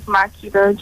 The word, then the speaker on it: Makida: My name is Makida Joseph. – Could you spell that? – M-A-K-I-D-A.